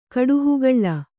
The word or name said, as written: ಕಾಡಹಾಗಲ